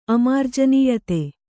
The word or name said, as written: ಅಮಾರ್ಜನೀಯತೆ